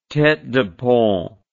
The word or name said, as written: tete de pont